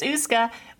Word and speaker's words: Uisce: Am I pronouncing that right? – Yep, it's Uisce.